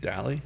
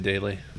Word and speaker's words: Dally: How you say your last name? Dally? – Dally. Yeah. – Oh, no shit. – You've been saying it wrong the whole time, but I'm saying daily.